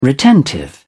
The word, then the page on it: retentive: British English